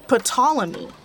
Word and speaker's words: Ptolemy: and she told me that it is supposed to be pronounced Ptolemy. Like, you're supposed to pronounce the P.